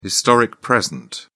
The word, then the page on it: historic present: British English